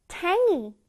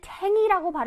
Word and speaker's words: tangy: 근데 이걸 어떤 사람들은 탱이라고 발음하기도 하고 어떤 사람들은 탱지라고도 한다? 근데 쌤은 탱이가 조금 더 귀여운 것 같아서 탱이라고 해. 탱이.